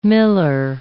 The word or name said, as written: miller